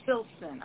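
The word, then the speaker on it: Silsan: Silson. Okay, I'm sorry, I was mispronouncing that, Silson.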